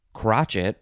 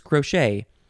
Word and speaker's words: crochet: It looks like it's pronounced crotchet, but it's not. It's crochet.